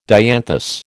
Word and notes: dianthus: American English

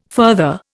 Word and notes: further: Indian

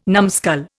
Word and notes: numbskull: Indian

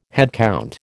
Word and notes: headcount: American English

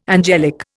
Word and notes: angelic: Indian

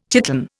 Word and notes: chiton: Indian

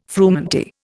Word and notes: frumenty: Indian

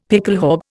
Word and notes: pickelhaube: Indian

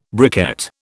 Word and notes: briquette: British English

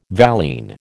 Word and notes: valine: American English

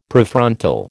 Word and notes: Prefrontal: American English

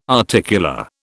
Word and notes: articular: British English

